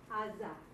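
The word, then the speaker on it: Aza (עזה): Used to be that the ayin is pronounced, but they lost it. But in the Septuagint, when they translated into Greek, the Ga sound came out. That's why it's Gaza.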